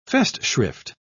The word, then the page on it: festschrift: British English